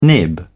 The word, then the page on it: nib: British English